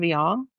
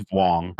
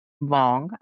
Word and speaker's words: Vuong: It's by Ocean Vuong. If I'm saying that correctly, I believe I am. – It's Vuong. – Vuong. I'm sorry.